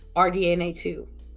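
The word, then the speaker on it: RDNA 2: Why does Sony get a pass for not officially disclosing anything like uh like RDNA or RDNA two. Did I say that right? Yeah, you said it right.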